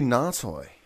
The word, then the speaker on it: Ngatai: That would be Ngatai. – Ngatai? – Just like a long inflection on the first. So Na, and there's just a quick tie on the end there.